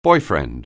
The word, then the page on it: boyfriend: anglais